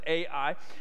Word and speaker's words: Ai: And what we see next coming for them is they're about to be in a battle with a city called Ai. At least that's how I think it's pronounced. It's A-I, but it's a battle city called Ai.